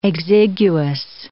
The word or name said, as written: exiguous